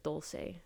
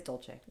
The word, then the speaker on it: Dolce: Wait, is it Dolce or Dulce? – I think it's Dolce. – Dolce. – It is Dolce. – Good. Italian.